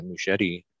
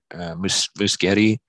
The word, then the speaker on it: Muschietti: And he even said that he's talking to Muschietti, Muschietti. – Yeah. – I think that's how you pronounce the last name.